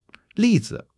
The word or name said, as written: li4 zi5